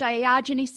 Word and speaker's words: Diogenes: Uh, yeah, and when you were talking about Diogenes syndrome, did I say that right? – Yeah.